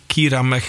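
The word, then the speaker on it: Kira Mac: En ik ga door met een nummer van de band Kyra Mac, als ik het goed uitsprekend. Kyra Mac kan ook. K-I-R-A, Mac.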